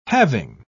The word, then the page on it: having: British English